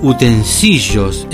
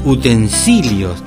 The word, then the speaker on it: utensilios: El ejemplo más claro de esta deformación se produce con la palabra utensilios, que es pronunciada frecuentemente utensillos en lugar de utensilios, porque le aclaro que la palabra utensilio no se escribe con L, se escribe con L y O.